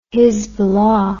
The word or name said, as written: hizbollah